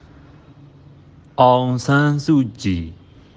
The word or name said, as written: Aung San Suu Kyi